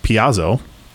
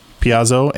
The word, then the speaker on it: piezo: Our conversation about what is I now know pronounced piezo. – Yeah. – He said, hey, just so you know, like piezoelectric blah blah blah is pronounced like this. It's not. And he specifically said, don't listen to the Brits.